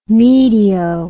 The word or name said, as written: medio